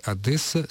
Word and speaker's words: Одесса: Поэтому вы, Светлана, и все ваше окружение, как вы пишете, не допускало ошибок, когда произносила, да и продолжает произносить «Одесса» с твердым «д».